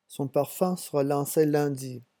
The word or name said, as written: son parfum